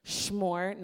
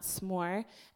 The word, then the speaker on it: s'more: And I think that was something that my family just did, and so I was convinced in my mind that it was schmore, not s'more. And I read it, and I saw how it was spelled, and I just thought it was one of those funny things, and it's just pronounced schmore, right?